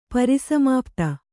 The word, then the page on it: ಪರಿಸಮಾಪ್ತಿ: pari samāpti